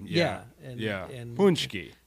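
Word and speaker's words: pączki: Yeah, did you get your punchki? – I that, did uh, several.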